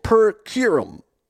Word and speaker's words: per curiam: The majority opinion, and Matt can pronounce this better than me, it's Latin, it is per curiam.